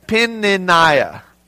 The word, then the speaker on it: Peninnah: Hannah, and anybody can pronounce the other wife's name? Every time I read it, I just call her Penny. Because I've tried, it's Peninnah.